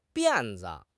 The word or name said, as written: 辫子